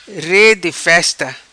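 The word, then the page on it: Re Di Festa: patua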